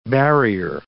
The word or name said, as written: barrier